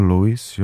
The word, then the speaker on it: Lewis: Luis Capaldi na vlnách Rádia Blaník. Řeknu vám to křestní jméno Luis, to je stejně zvláštní. Ono se to píše Lewis s dvojitým V a čte se to Luis, jo, Luis Hamilton, to všichni znáte, závodník Formule 1, Luis Capaldi.